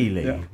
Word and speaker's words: Ely: Ely, yeah.